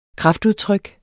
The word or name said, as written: kraftudtryk